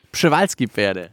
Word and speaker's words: Przewalski-Pferde: Ich zähle 6 Tannenbäume für die Trampeltiere und die Przewalski-Pferde. – Genau, die P-Pferde. Schön, dass du den Namen vorher richtig ausgesprochen hast.